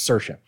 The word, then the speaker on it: Saoirse: One of my other favorite ones is Saoirse. There's an actress named Saoirse. And it's spelled like S-A-O-R-I-S-E, I think. And it's Saoirse. Anyways, must have been great in class.